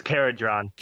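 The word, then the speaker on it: Kharadron: Man, how do you say this? Carhuden? – Carhuden? – Caradron? I don't know. – I think it's Caradron. – Caradron. So I'm just Caradron. Terrible at pronunciation, no matter what it is.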